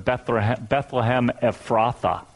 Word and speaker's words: Bethlehem Ephrathah: Sometimes when you hear this story read, especially when it comes from the book of Micah, this little difficult to pronounce word, Bethlehem Ephrathah is how it's said in Micah, which is a part of Judah, the southern part.